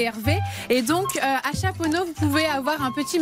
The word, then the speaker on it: Chaponost: Chaponneau. Chaponneau, on ne prononce pas le OST.